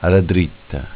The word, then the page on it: AllaDritta: dialetto